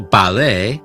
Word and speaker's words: ballet: Entonces, recuerde en francés, muchas palabras terminan con una T muda, lo que significa que la T final no se pronuncia. Por ejemplo, en palabras como ballet, cabaret o buffet, la T al final es completamente silenciosa.